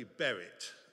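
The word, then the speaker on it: Berrit (ברית): That's pronounced something like Berech or something like that. It, it's going to be Berrit this morning, all right? That's, that's the word, Berrit, okay? Remember it, not the pronunciation.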